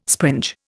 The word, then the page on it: springe: Indian